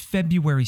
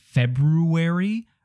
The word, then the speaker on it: February: Is it February or is it just February? Why is this so difficult? What is that first R doing there? That's not how we pronounce it. Nobody puts that first R in there.